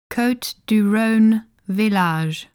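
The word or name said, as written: Côtes du Rhône Villages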